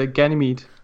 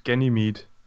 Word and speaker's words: Ganymede: Det udtales uh, Ganymede. – Der fik du mig så. – Også på engelsk, Ganymede.